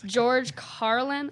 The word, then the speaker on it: George Carlin: George Carlin, I think.